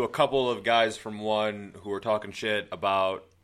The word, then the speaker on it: ONE: Do they pronounce it one or is it O-N-E? – It's O-N-E, but they're one. Yeah, they pronounce it one. Like S.H.I.E.L.D.